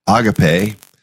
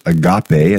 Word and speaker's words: agape: Some you would hear it said sometimes agape, and that's how I've always said it. But I've been around some friends more recently, especially in England. Agape is how they say it. So, but the agape, I'll say it that way.